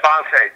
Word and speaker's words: Bonta: Yeah, Bonta, just like Dante, but with a Z.